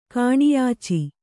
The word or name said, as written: ಕಾಣಿಯಾಚಿ